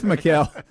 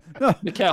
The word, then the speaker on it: Mikal: It's Mikal. – Mikal.